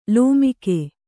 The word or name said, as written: ಲೋಮಕ